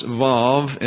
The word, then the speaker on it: vav: The next character you see is a wow, a W, and it's pronounced vav, and is transliterated as a V in English transliteration.